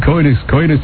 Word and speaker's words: coitus: All right, the next one, you got the pronunciation of coitus right? – I think so, yes. – Okay. – Coitus interruptus, whatever that is. – Coitus. That is the okay coitus. – Number. – Okay. – And that's how you say it, right, Buzz? – Coitus interruptus. – Coitus. Okay, that's what we want.